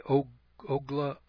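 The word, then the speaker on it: Ogallala: Kansas sits astraddle of the Oglala Aquifer, or the Oglala Aquifer, I believe is the way you pronounce it.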